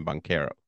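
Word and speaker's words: Banchero: I think he says Bankera. He pronounced his name Bankera.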